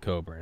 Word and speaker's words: Cockburn: Pronounces it Coburn, it's Cockburn.